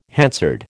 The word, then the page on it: Hansard: American English